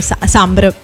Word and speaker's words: Sombr: Guarda, in realtà credo che sia più corretto dire Sambari, però la pronuncia che lui stesso dà al suo nome sarebbe Sombr.